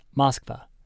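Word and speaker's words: Moskva (Москва): A word that looks like makpa is pronounced Moskva, and a word that looks like pektapa, pronounced restaurant.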